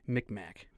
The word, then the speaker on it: Mi'kmaq: This is from the Mi'kmaq tribe. I hope I'm saying that right. M-I-C-M-A-C. Mi'kmaq. – Okay. – Is that correct? – That sounds about right.